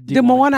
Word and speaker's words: Des Moines: Don't forget, I will be in Des Moines, Iowa. Did I say that right? – Yep. – Des Moines, Iowa.